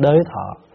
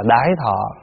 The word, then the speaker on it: đới thọ: Có chỗ đọc là đái thọ, đọc đúng là đái thọ. Nhưng mà như hôm trước mình học vậy đó, những cái từ nào mà nó, mình tránh những cái từ thô để người ta có thể người ta tác ý, mình tránh đi, cho nên mình đọc là đới thọ, đọc trại chữ, để tránh trường hợp là người ta tắt cái ý.